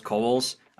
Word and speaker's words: Cowles: Before we begin, real quick, my last name is pronounced Cowles. I don't Coles. Hold it against you, but I know people care about getting that right. So it's Coles. – Clayton Cowles. So when you do your introductory recording later, that's how it goes. Cowles rhymes with Knowles.